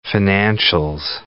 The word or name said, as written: financials